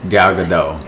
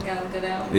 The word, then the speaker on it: Gal Gadot: How do you say her name? Wonder Woman. – Gal Gadot. – Gal Gadot. – Yeah.